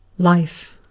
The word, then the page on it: life: British English